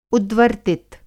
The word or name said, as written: उद्वर्तित